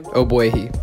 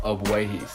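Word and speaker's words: Ogbuehi: Obwehi. – Obwehi. Cedric Obwehi. – The G is silent. It's like it's really weirdly spelled, but it's Obwehi.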